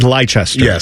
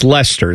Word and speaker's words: Leicester: It looks like Leicester. – Yes, but it's Leicester is how they say it.